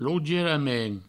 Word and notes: lougierament: provençal; lougieramen

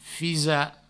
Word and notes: fisar: fisa; Provençal

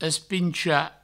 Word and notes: espinchar: provençal; espincha

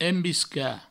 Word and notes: embiscar: embisca; Provençal

